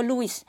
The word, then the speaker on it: Louis: 路易巴斯德，他就是那个 Louis，其实就跟 LV Louis Vuitton 一样，就是在法文那个 Louis 的那个 S 是不发音的。